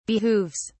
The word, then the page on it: behooves: British English